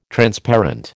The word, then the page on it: Transparent: American English